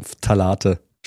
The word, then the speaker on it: Phthalate: Du hast insofern schon gut gestartet, dass du Phthalate schon richtig, also richtig ausgesprochen hast.